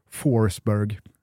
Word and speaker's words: Forsberg: Eller ska vi säga Emil Forsberg? Jag vet inte om du såg det? – Forsberg. – Eh, svårt med uttalet där. Ja, men de hade ju bakat in ett U då efter O som i engelskans four eftersom man nu har gjort mål i fyra raka ligamatcher.